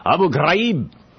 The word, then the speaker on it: Abu Ghraib: That's a tough one to pronounce if you want to say it the way that they actually say it. Abu Ghraib.